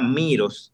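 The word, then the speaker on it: Meross: Entonces, también, si quieren interruptores inteligentes, o sea, perdón, plugs eh, inteligentes, la marca Miros, se escribe Meros, es muy buena y barata.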